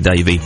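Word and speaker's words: Davy: It's about a 25-second video on my Twitter feed, which is at Charlie Davey.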